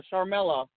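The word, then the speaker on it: Sharmell: Oh, Charmella. How do you say her name? – I'll take it. Queen Charmel. Yeah, there you go.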